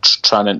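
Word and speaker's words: Tranent: You call it Trenent, because that's where he comes from, and it's Trenent, mate.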